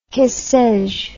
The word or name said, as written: que sais je